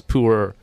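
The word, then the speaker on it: Pu'er: and the way that they pronounce it is actually like pu-er, like Winnie the Pooh and er, like ergonomic, so it's pu-er.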